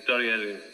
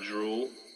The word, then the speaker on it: Dorielu: Dorielu is the Japanese pronunciation of that tune. In English, it just sounds like drool, but yeah, the Japanese Dorielu.